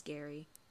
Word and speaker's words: Gerry: His name is Gary. – Aw. – But it's spelled Jerry with a G. Oh. Like G E R R Y.